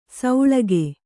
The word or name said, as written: ಸೌಳಗೆ